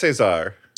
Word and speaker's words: César: She was she previously won a César or César. How do you pronounce it? – I think it's César.